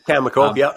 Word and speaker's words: Cameco: At the same time, you know, a stock in the uranium sector, Cameco, I never know how to say it. – Cameco, yeah.